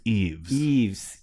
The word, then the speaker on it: Yves: I mean, Yves is definitely a French name. And then the last name is P-E-R-R-E-T. – I've heard it pronounced Yves. – Yves, you're correct.